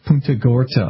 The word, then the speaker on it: Punta Gorda: You'll have to excuse me if I mispronounce the name of the town, but I think it's Porto Gordo, Florida. – Punta Gorda, I believe it might be.